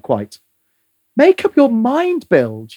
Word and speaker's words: Bilge: B-I-L-G-E.